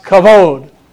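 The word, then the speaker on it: kavod (כבוד): The Hebrew word in Psalm 19, verse 1 for glory is a Hebrew word pronounced kavod.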